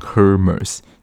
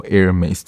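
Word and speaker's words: Hermès: 那他们的招牌微笑呢，就是 Hermes，其实是那个非常名贵那个精品包的名字。我本来以为那个就是念 Hermes，但是好像比较真正靠近那个发音，就是 H 是不发音的，就真的是念作 Hermes 这样子的一个微笑，好像就是要练成这样子一个微笑才能够就是让。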